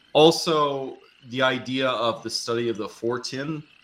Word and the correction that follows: Fortean: am I pronouncing that right, Trillian? – Can you spell it? – No, I'm not very good. – So, the Fortean magazine? – Yes.